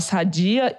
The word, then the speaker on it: Sadia: a marca tá lá há tantos anos que a população lá se pronuncia com perdão da minha pronúncia em árabe, sadia, né? Que eles acham que é um jogo de palavras com Saudi, né? De Arábia Saudita.